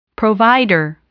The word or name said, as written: provider